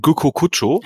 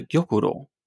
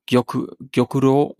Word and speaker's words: Gyokuro: Ja, die richtige Aussprache wäre Gyokuro. – Also die Betonung auf die erste Silbe immer legen, ne? – Ja, eigentlich sind die meisten eher gleichberechtigt. Also Gyokuro, also das R ist, glaube ich, der Knackpunkt.